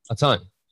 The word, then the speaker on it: Atun: Now, do we see a more, I guess, a more modern sense of weaponry when they get to the city of Atuna? Am I saying that correctly? – A ton. Yeah, so not necessarily more in the sense of, like, you know, we get towards guns or anything.